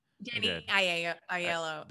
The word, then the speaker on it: Aiello: And the age difference between the character of Danny Aiello's character, Aiello. Johnny Aiello's, did I say that wrong again? – Danny Aiello. – There you go.